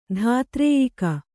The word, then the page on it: ಧಾತ್ರೇಯಿಕ: kannada